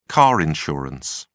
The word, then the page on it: car insurance: British English